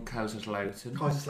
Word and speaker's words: Kaiserslautern: One from Kaiserslautern. – Well played. Good pronunciation.